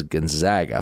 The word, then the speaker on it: Gonzaga: By the way, it is Gonzaga. I finally got that clarified. Even though in Italy, the Saint Aloysius Gonzaga is how it'd be pronounced. For whatever reason, in the Pacific Northwest, the name of the school, they pronounce Gonzaga. After all, they're sometimes called the Zags. So make sure you get that right.